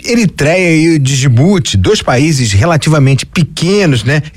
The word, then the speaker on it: Djibouti: Agora, indo diretamente à questão, dizer que na nossa pronúncia aqui em Moçambique, o nome Djibouti é mesmo Djibouti, então vão ficar à vontade eu dizer Djibouti diretamente, como nós dizemos aqui em Moçambique.